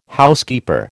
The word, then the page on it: Housekeeper: American English